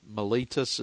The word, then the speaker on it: Miletus: But it was specifically with the elders from the church at Ephesus in a place called Miletus.